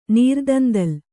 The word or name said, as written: ನೀರ್ದಂದಲ್